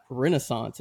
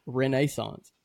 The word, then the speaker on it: Renaissance: I'll tell you a little bit about the Genesis at the – it's not Renaissance. It's Renaissance. – Oh. So, I heard that. Gotta have the correct pronunciation on that.